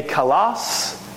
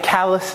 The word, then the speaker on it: kalos: He would be a kalos diakonos, not a callous deacon. I know the Greek pronunciation could sound like that.